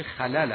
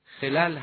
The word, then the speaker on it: خلل: البته تلفظ درست این واژه خلل است، با دو فتح دو حرف اول. اما خلل هم بسیاری از دوستان خصوصاً در زبان معیار شنیده شده.